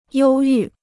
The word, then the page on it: 忧郁: yōu yù